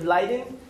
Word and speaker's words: Leiden: He got his master's degree in 1987 in physics at the University of Leiden.